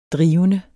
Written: drivende

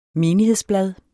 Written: menighedsblad

